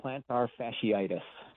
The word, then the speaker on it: plantar fasciitis: This was also just announced that Reds' Jonathan India, he is on the injury list with plantar fasciitis. Did I say that correctly? – Pretty good go at it. It's plantar fasciitis.